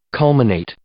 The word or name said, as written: culminate